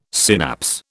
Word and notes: synapse: British English

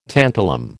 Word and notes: tantalum: American English

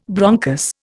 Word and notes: bronchus: Indian

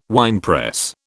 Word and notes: winepress: British English

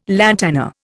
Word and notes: lantana: Indian

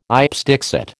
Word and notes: ipse dixit: American English

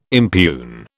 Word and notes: impugn: British English

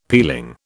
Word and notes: peeling: British English

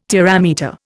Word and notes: terametre: Indian

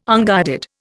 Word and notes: unguided: Indian

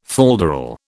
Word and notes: falderal: British English

